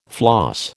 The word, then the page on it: floss: American English